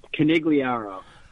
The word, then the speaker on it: Conigliaro: My last name is pronounced Conigliaro.